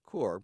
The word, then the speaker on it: Corps: Well, I haven't heard him say that, but it is Corps, Marine Corps, even though it ends in an S or a PS. We don't pronounce this.